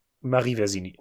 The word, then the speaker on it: Marie Versini: Welche Filmfigur wurde für die im November verstorbene Französin Marie Versini, Moment, die Betonung ist falsch, Marie Versini ist mir scheißegal.